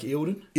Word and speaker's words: Eildon: Lake what? – Lake Eildon. – Eildon? – Yep. – How would you spell that? – E-I-L-D-O-N. – E-I-L-D-O-N, Eildon. Okay.